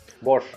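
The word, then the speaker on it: Bosz: Или Босс, я честно скажу, как правильно произносится. Голландец, наверное, Босс. Бош. А, Бош все-таки, да?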